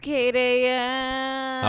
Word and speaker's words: JackAM: It's Kate AM.